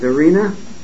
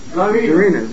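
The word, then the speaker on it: Tsarina: Arena? – Is that the arena? – Really? Russia. – No, the Tsar is male, and what's the female? – Zarina.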